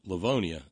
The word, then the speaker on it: Livonia: Livonia, Michigan, pronounced Livonia, says Brother Deacon Asa.